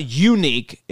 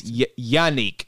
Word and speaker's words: Yannick: They it's not unique. It's Yannick.